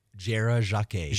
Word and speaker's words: Jera Jacquet: Jera Jacquet. – Jacquet. Okay. That's more the French pronunciations.